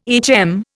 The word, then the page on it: HM: Indian